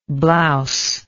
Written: blouselike

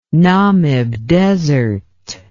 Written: Namib Desert

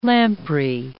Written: lamprey

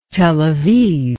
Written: Tel Aviv

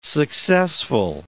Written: successful

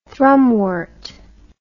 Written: thrumwort

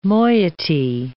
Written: moiety

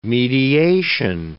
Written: mediation

